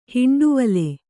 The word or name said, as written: ಹಿಂಡುವಲೆ